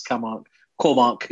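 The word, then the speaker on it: Kåmark: Yeah, it is actually. It should be O. It's an A with a circle, but internationally they say Cormack or whatever.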